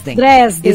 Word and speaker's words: Dresden: Dresden. Dresden.